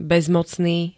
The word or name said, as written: bezmocný